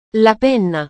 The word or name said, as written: la penna